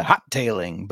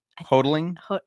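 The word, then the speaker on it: Hotaling: I was going to say the hot tailing, but it's not hot tailing. – No. – Hoteling.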